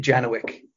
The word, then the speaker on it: Janowczyk: We pronounce it Janowczyk, which I think is a very Americanized version of that.